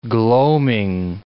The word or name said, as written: gloaming